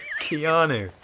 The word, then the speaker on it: Keanu: Keanu. – Keanu, is it? – Yeah. – I've always said Keanu.